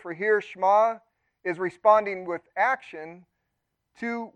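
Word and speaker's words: shema (שמע): Now, I'm not a Hebrew expert, but the Hebrew word for hear is a word shema, or pronounced shema, like one syllable.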